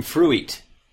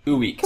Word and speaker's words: fruit: that thing made from fruit. – Fruit. Yeah, no, it is difficult. It is difficult. I really sympathize. – Because it's spelled like F-R-U-I-T, so it's, you would think you'd pronounce it ooit.